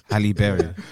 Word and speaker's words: Halle Berry: Hailey Berry? – What? – Hailey Berry. – Hailey Berry. – Bailey. Hailey Bailey. – It's quite a hard name to pronounce. – Halle Berry. You're right.